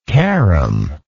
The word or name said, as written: carrom